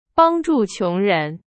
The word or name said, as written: 穷人